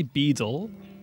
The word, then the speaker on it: Beedle: I'd say Beedle. Sure. But I check people would be like Beedle. – Yeah. – Because they pronounce every single sound. Italians would be the same. A posh one would be Beedle.